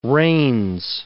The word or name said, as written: reins